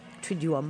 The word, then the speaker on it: Triduum: We've got some great Triduum. Did I say that right, Todd? Triduum. – Triduum. Triduum. It's hard because it's two U's right in a row.